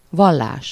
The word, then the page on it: Religion: /ʁeliˈɡjoːn/